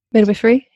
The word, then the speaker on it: midwifery: And she went into, and I hope I'm saying it right, but midwifery? – Midwifery, yeah. – Midwifery. Oh, wifery, not whiff.